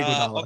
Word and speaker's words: Iguodala: Iguodala. – Andre Iguodala.